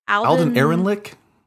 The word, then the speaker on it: Alden Ehrenreich: Alden Ehrenreich? – Yes. Does that sound right?